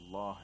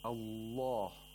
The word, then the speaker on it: الله: In the phrase بالله, the lam of the majestic name of Allah is pronounced with ترقيق, with thinness. Now, the thing with the letter lam is that it is pronounced with ترقيق all the time, except in the majestic name of Allah, under certain conditions.